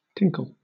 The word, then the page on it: tinkle: British English